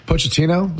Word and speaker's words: Pochettino: By the way, Pochettino, is that your pronounces the coach's name? – Yeah.